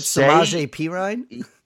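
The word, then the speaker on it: Samaje Perine: Say Samaj Perrine, who I never pronounce his name correct, or the same two times in a row. – Yeah. Isn't it Samaje Perrine? – Yeah, say he runs away with that running back one role in Washington this year, and it actually is good.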